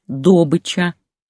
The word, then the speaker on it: добыча: Именно «добыча» с ударением на первом слоге, как свою деятельность именуют матерые нефтяники.